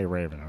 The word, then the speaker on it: Raven: I love it when people try to pronounce it the real Anglo-Saxon, the old English. They'll go, Hafen. I have people now that still go, Hafen. I'm going, you can just say Raven, all right? – Yeah.